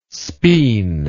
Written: spean

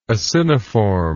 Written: aciniform

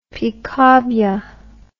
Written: picabia

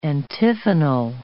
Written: antiphonal

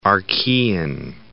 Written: Archaean

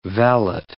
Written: valet

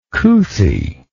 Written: couthie

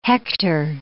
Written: hector

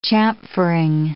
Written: chamfering

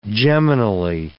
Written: geminally

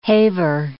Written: haver

